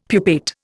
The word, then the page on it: pupate: Indian